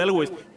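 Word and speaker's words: Alvvays: Y ya, ya recordé que es lo que te iba a decir de lo de Elwes. Que aparte, justo tiene poco que hablamos de ellos en el temático de impronunciable. Ah, sí, sí. Ahí los pusimos porque pues se escribe A L V V A.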